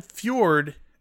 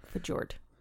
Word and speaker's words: fjord: There wasn't a fjord in that shot, though. – Fjord. – Fjord is the proper pronunciation. Fjord.